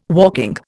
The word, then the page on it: walking: Indian